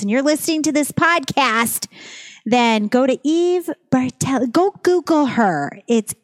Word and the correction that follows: Battelle: Do I always say it wrong? I always say Battelli. – You just added an R. – I did. Battelle. – Yeah, you said Bartel. And I'm like, nope, Battelle. B-A-T-E-L-L-E. – E-V-E-B-A-T-E-L-L-E.